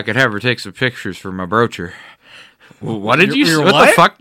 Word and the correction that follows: brochure: My brocher. You mean brochure? What?